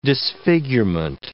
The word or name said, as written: disfigurement